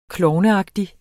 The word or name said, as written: klovneagtig